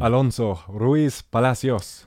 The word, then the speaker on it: Alonso Ruiz Palacios: How would you say that name? – Alonso Ruiz Palacios. – Would you pronounce? Yeah, that's probably a little better.